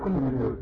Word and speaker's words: Sindur: You will see Krishna, he is putting the what is it called? Sindur? – Sindur. – Sindur. Sannyasis don't know these things. Did I say it correctly, Maharaj? – That's me.